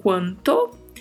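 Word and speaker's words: cuanto: Então vamos lá, começando com o advérbio quanto. Quanto, que significa quanto, né? Quanto. Muito cuidado aí com a pronúncia, tá? Temos que pronunciar todas essas vogais aí no início. Quanto.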